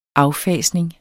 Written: affasning

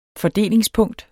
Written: fordelingspunkt

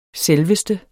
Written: selveste

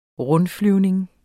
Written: rundflyvning

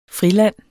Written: friland

